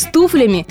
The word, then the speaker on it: туфлями: То есть в случае с туфлями, да-да, еще раз, не с туфлями, а именно туфлями, всегда ударение сохраняется на первом слоге, на «у».